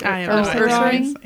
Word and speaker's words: Ursaring: Is that how you say it? – Ursaring. – Yeah, Ursaring.